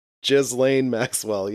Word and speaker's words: Ghislaine Maxwell: Ghislaine Maxwell. – Is that serious? – Yes. Ghislaine Maxwell, yes.